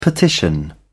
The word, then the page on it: petition: British English